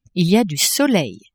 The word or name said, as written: il y a du soleil